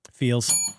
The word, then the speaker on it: Feals: I love something called Feels. – Feels. – And it's spelled F-E-A-L-S, which I think when people hear the spelling, they're one eyebrow.